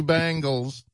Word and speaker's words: Bengals: Teach him how to say it. – Bengals. – Bengals. – It's an E, not an A.